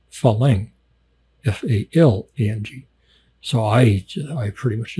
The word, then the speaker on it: farang: It's Falang. The Thais say it more with the emphasis on the first syllable, like Falang, Falang, like that, Falang.